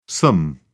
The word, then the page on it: some: American English